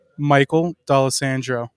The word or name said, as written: Michael D'Alessandro